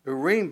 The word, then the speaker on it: Urim: the Urim, would probably be the best way to pronounce that, Urim before the Lord.